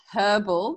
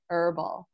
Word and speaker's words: herbal: And for anyone listening in Australia, it's herbal because we pronounce the H. If anyone is um, uh, confused by that, I always find that funny. Americans say herbal.